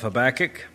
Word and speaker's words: Habakkuk: We are looking at the first chapter of Habakkuk, right after Nahum and right before Zephaniah. I pronounce it Habakkuk. Some people have said Habakkuk, but we're going to go with Habakkuk.